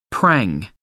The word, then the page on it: prang: American English